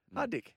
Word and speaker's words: Hardik: Well, I was just talking to you about Hardik Pandya last night. And amazing cricketer. – Wrong pronunciation. – Go again. – Hardik. – I said that. – Yeah. That's Hardik. What I thought you said, Hardik.